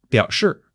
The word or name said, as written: biao3 shi4